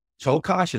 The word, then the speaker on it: Czolgosz: Leon Czolgosz.